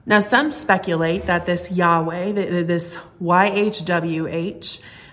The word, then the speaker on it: Yahweh: Now, we don't know how it's pronounced for sure, um, but most often we pronounce that Yahweh.